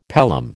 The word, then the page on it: pelham: American English